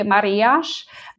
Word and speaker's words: Marias: My favorite is called Marias, or Pauzinho de Marias, and it's just spelled like Maria's. When there's an S at the end of a word here in Portugal, we say it like a sh, so it can be a little confusing, but Marias is definitely one of my favorites.